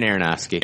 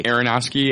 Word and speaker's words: Aronofsky: Is it Aronofsky? Am I saying that right? – Darren Aronofsky. – Aronofsky, yeah.